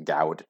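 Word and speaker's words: Gawd: And it seems to be effectively led by Gaud. Or I'm going to pronounce it Gaud, because it's G-A-W-D.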